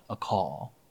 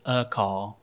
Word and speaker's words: Akal: And I remember coming across the song that my sister sent me called A Call, A-K-A-L, sometimes is how it's spelled, but it's A Call.